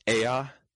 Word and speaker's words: Ea: And then Ea, E-A, it's two syllables.